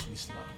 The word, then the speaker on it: Wxla: The pronunciation of my name is Wxla.